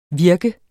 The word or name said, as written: virke